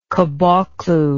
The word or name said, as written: caboclo